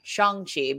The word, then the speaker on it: Shang-Chi: Shang-Chi, which is how you pronounce his name, Shang-Chi, because they make a joke about that.